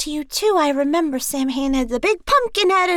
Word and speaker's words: Samhain: It's pronounced Samhain, but the real Ghostbusters cartoon called this creature Samhain.